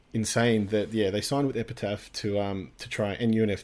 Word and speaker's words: Epitaph: And they actually signed with Epitaph, mm-hmm.